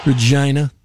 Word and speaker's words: Regina: Regina. – That's it. That's how you say it.